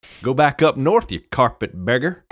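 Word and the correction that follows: carpetbagger: I know it's carpet bagger. I was mispronouncing it.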